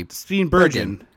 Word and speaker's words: Steenburgen: So anyway, the owner of the company drops by the house where poor Mary Steenburgen is like, I keep saying it wrong. – It's G, hard G. – Poor Mary hard G. – Steenburgen. – That's a hard G. – Oh, it is Bergen.